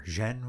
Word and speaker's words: Jean: Because, okay, for people who aren't film assholes, The Grand Illusion is directed by John Renoir, and which I'm mispronouncing. – Yeah, I got Renoir. – Jean Renoir. Yeah.